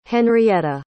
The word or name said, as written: Henrietta